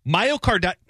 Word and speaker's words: myocarditis: You think, which one sounds right, carditis? Myocarditis. – Spell it. – Myo. – Yep. – Car. – Yeah. – Ditis. – There you go. – Nailed it.